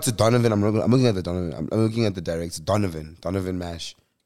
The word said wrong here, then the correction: Marsh: Marsh, excuse me.